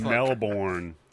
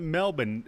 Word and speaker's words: Melbourne: They pronounce it Melbourne. – Who says Melbourne? – They pronounce it Melbourne, not Melbourne. – No, that's how you pronounce it with an Australian accent.